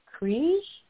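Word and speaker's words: Krige: And I met for the very first time Alice Krieg.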